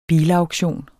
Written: bilauktion

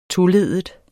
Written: toleddet

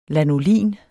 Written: lanolin

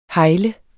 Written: hegle

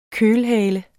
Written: kølhale

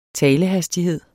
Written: talehastighed

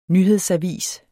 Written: nyhedsavis

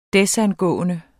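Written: desangående

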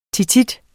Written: tit

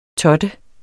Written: TOTTEDE

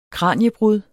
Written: kraniebrud

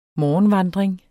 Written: morgenvandring

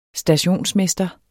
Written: stationsmester

